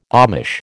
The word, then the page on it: Amish: American English